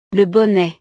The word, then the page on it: le bonnet: frances